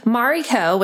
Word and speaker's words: Marri: Mariko, which is my full name, is spelled M-A-R-I-K-O. It's a traditional Japanese name. I'm part Japanese. Mari, my nickname, is spelled M-A-R-R-I because my parents thought that if they stuck with M-A-R-I, people would say Mary or Marie. – Yep. – So it's M-A-R-R-I, but M-A-R-I-K-O.